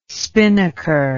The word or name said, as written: spinnaker